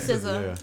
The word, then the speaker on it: SZA: SZA.